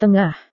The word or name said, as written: tengah